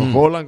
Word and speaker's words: Haaland: Han tomado mucho de meme a Haaland, o Haaland, como se pronuncia, por el tema de que se cortó la melena.